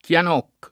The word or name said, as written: Chianocco